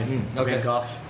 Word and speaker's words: Van Gogh: Like, so one of my favorite, as far as painters go, Van Gogh is my Van Gogh. To pronounce it properly. – Is it really Van Gogh? – Yeah. – Is it? – Because the Dutch pronunciation.